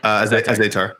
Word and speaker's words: Azaitar: The guy that lost Azaitar.